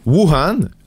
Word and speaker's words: Wuhan: Wuhan, le berceau de la COVID-19, très chanceux, n'a pas à être francisé si vous vous le demandiez, c'est la prononciation exacte en chinois.